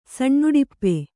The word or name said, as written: ಸಣ್ಣಉಡಿಪ್ಪೆ